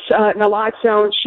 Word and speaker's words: naloxone: And naloxone, is it naloxone? Am I pronouncing that right? – Yeah, so it's interchangeable. It's naloxone, short Narcan.